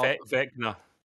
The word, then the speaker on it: Vecna: You're going to play as the villain, Venka, I guess is pronounced how you pronounce it. – And you're gonna have all Vecna.